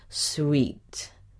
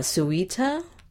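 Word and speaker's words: suite: This word is pronounced sweet, like in a hotel, sweet, not suita, sweet.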